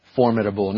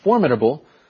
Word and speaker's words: formidable: Formidable. Now, that is a word bank word. And just so you know, you can pronounce it formidable, or you could say formidable.